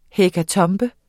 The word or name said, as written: hekatombe